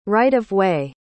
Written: right-of-way

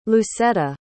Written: lucetta

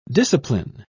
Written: discipline

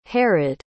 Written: harrod

